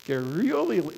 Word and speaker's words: garrulity: I can't even say the word now. My tongue's twisted. Garrulity. How do you say that? Garrulity. There you go.